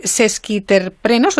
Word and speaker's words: sesquiterpenos: entonces, claro, tenemos más eh, sesquiterprenos, ¿lo he dicho bien? – Exacto, muy bien.